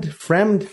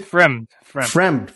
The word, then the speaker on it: Fremd: Also on the preliminary card, you had Anthony Hernandez defeating Josh Fremd. How do you pronounce it? Fremd? Friend? Fremd? – Fremd.